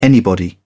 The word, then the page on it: anybody: British English